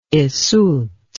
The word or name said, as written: yseult